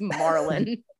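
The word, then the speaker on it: Marlynn: No, that's Marlon. Like Nemo's dad.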